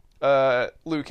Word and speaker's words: Liu: So he tells Liu Kang, by the way, I'm going to call him Liu Kang because his character's name is Liu Kang, which is spelled L-I-U. – And not Liu.